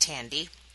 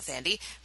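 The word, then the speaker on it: Thandiwe: Well, and I found out that Thandi Newton's name is pronounced Tandy also. – Oh. – Not Thandi.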